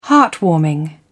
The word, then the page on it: heartwarming: British English